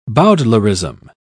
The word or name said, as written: bowdlerization